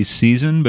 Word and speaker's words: SXXN: The next track in this three-pack is, I'm going to say Season, but it's spelled all caps S-X-X-N.